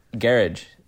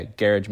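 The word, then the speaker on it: Gearóid: His name looks like Geroid or Gerald. but it's actually pronounced Garage, as I understand it. So that's, yeah, Garage McDade.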